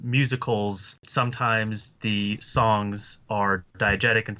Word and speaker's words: diegetic: Like, you get kind of this musical, that kind of the term, what was the term you used before, like diegetic or whatever, or am I saying that right? – Yeah, it's a term that just references sound in movies. So, like, diegetic versus non-diegetic sound.